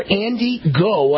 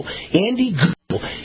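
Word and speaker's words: Andy Ngo: Andy Go. I think that's how you pronounce it. The N is silent. N-G-O. Andy Go.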